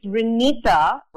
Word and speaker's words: Reenita: It's Reenita.